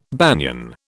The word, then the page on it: banian: British English